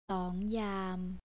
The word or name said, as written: songyam